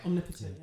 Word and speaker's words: omnipotent: Omnipotent? Did I say that right? – Yeah, omnipotent. Yeah.